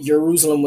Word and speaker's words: Jerusalem: There wasn't any J's back in the day, but they pronounced it Jerusalem with a Y.